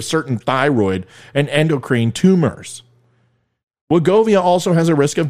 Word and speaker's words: Wegovy: Wegovy, pronounced Wegovy, all right, Wegovy, is a synthesized version of a gut hormone that curbs appetite.